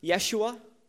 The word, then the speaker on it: Yeshua: The name Jesus is actually pronounced Yeshua in Hebrew, and it is the name Joshua. By the way,